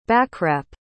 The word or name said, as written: backwrap